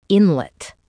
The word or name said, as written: inlet